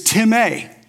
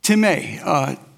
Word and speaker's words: timē: So since you know a little Greek, the Greek word for honor is timē. Timē. T-I-M plus a long A. Timē.